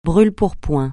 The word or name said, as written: brûle-pourpoint